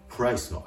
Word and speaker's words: price: 英語の R の発音は、発音する直前小さくうというような形で、プライス、プライスと発音してあげるところがポイント。これを L の発音と混同しないように注意しましょう。で